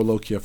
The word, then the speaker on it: lochia: or more of the lochia? Am I saying that correctly? – Lochia is the right word.